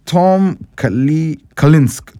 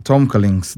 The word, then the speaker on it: Tom Kalinske: No. It's Tom Kalinske. Tom Kalinske. That's his name. K-L-I-N-S-K-E.